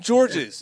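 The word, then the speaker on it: Georges: No one ever calls him George's. – But that's his name. If you go to France, S is silent.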